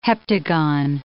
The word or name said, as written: heptagon